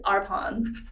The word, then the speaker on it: Arpon: Wait, isn't that how you pronounce your last name, Arpon? – I pronounce it Arpon.